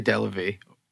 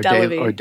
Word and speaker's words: Delavie: How do you pronounce it? Yeah, I say, well, I have a Boston accent, but I say Delavie or Delavie. Delavie. It all depends.